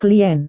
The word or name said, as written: klien